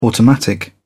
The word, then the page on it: automatic: British English